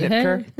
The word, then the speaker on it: Schnitker: Schnitker. Hey. – Could you want to spell it? – S-C-H.